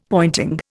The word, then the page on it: pointing: Indian